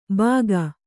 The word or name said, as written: ಬಾಗ